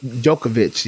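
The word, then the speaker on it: Djokovic: Jokovic, yes. I'm about to say, I don't know why I know his name, and I don't know why I saw the D and I automatically couldn't pronounce it.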